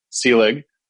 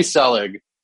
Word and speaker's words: Selig: or it's the one I accept after the actual pronunciation, which is Selig. So just thought I'd put that out there to whoever's listening to this. But if you call me Selig, we can still do podcasts together.